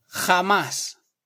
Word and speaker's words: jamás: Also, the J is often pronounced like H. So instead of jamás, you'll hear jamás.